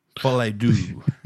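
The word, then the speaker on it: Folie à Deux: Yeah, look, we've got The Joker, Folie d'Or. Is that how you say it? – Folie d'Or.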